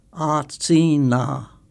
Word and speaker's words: atsina: Dana Tiger taught me how to say the word cedar in Cherokee. It tangled my Aryan tongue at first, so I practiced until it felt natural. Passing my arboreal elder tonight, I say Atsina aloud, then repeat, certain it hears and can understand the sound we humans once assigned to an aging sentinel guarding our hill.